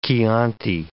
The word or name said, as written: Chianti